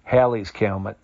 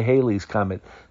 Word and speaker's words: Halley's Comet: Now, some people pronounce it Halley's Comet. Some people say Halley's Comet. Some people call it Halley's Comet. For all intents and purposes, we're going with Halley's Comet.